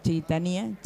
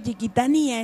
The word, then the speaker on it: Chiquitania: No se dice Chiquitania, es Chiquitania.